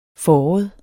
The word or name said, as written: fåret